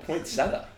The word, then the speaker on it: poinsettia: The only time I've heard it called the way Rudy says it is the football bowl game, the college football poinsettia bowl. But everywhere else it's poinsettia. – So then that's probably right then. They would know. – It's a silent E, Rude.